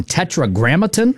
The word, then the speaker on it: Tetragrammaton: And this is on Tetragrammaton? Is that how you say it, the label? – Yes. Yeah.